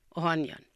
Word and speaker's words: Ohanian: My maiden name was Maryam Ohanian. – Can you spell that, please? – Maryam, M-A-R-A-M, Ohanian, O-H-I-A-N, Ohanian. – Barbara? – O-H-A-N-I-A-N. – O-H-A-N-I-A-N.